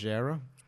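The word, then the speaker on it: Leggera: Oz Racing Ultra Legaras. – They're the ones I was looking at for the Integra. – I believe it's why? – Pronounced Legera.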